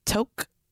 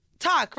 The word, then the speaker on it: toque: How do you pronounce T-O-Q-U-E? T-O-Q-U-E? Toque Blanche? Toku? Take. – Where is the A? – Talk, right? Just talk.